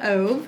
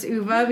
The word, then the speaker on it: Ove: Before I said Ove, but now I know that it's Uva because that's how the narrator, George Newbern, pronounced it.